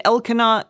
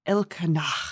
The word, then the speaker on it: Elkanah: And Elkanah, is that, am I saying this right? Elkanah.